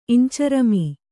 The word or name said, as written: ಇಂಚರಮೀ